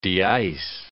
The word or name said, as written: deice